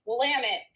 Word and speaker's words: Willamette: And I knew someone who went to Willamette High School and told me that their cheerleading squad used to cheer, it's pronounced Willamette, damn it.